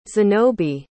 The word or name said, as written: zanobi